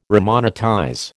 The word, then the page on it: remonetize: American English